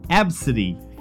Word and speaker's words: ABCDE: Tracy Redford told ABC affiliate KABC her daughter's name is ABCDE, which is pronounced Absidy. you know, almost like absinthe but not quite.